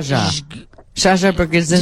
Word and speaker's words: Brzezinski: But you say Z as sh. – It's Zha. – Like Zha. Zha Brzezinski.